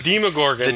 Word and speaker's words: Demogorgon: It is Gordon. Demogorgon. – Gorgon. – Gorgon, yes. Gorgon. – Not Gordon. – The Demogorgon.